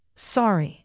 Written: sari

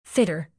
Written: fitter

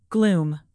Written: gloom

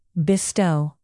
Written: bestow

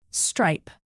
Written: stripe

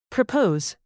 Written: propose

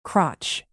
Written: crotch

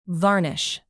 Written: varnish

